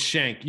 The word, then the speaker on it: Schenck: It's Schenck. You were close. Schenck. Everybody says Schenck. Story of my life.